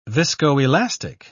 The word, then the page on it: viscoelastic: British English